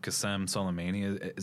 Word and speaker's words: Qasem Soleimani: which was the recent assassination of Qasem Soleimani. Is that how you pronounce his name? – Yes.